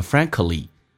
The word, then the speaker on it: frankly: 这个 a 趋向一发 a 就变成 frankly frankly frankly，所以以前说成 frankly 美式发音 frankly，你会发现有很大的差别。